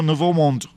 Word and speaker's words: Nouveau Monde: portada de la nueva revista internacional Nouveau Monde, si no lo pronuncio bien, usted corríjame. – Sí, no, Nouveau Monde.